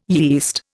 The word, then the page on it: yeast: Indian